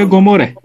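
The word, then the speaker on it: Gomore: Eylül ayının son gününde HVP Kitap Kulübü'nde yeni bir kitapla Yakup Kadrika ve Osmanoğlu'nun e, Sodom ve Gomorra'sı ile nasıl okunuyor bu? – Doğru abi Sodom Doğru. ve Gomorre.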